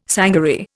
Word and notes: sangaree: Indian

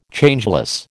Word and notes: Changeless: American English

